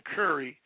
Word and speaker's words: Currie: Now, for those of you who don't know, my last name, Curry, even though I spell it C-U-R-R-I-E, but Curry, C-U-R-R-Y, is actually a spice.